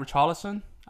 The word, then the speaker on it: Richarlison: I'm just saying, I think Richarlison, like, I can't say his name right, but whatever. If a Liverpool player went at Richarlison, I think I said his name right then. – You said it right both times.